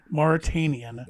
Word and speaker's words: Mauritanian: I'm gonna butcher this name, but it's the Mauritanian. – It's still the Mauritanian. – Mauritanian. – There you go.